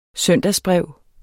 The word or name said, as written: søndagsbrev